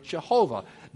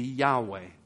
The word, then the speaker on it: Yahweh: That would be one way of pronouncing those four letters. There were no vowel pointings originally in the Hebrew Old Testament. Most people today would believe, actually, a more accurate way to pronounce it would be Yahweh.